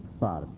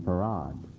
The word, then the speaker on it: Fard: He didn't tell them to call him Fard. He told his followers to call him Farad. So the spelling, the pronunciation of F-A-R-D is Farad, though it's not spelled that way.